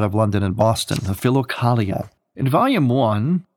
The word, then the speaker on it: Philokalia: Again, it's called the Philokalia. That's spelled P-H-I-L-O-K-A-L-I-A, Philokalia.